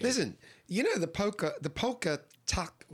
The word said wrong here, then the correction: Polka Tulk: How do you say it? Polka tulk. – Yes. – Polka tulk blues band. – Yeah, that's right.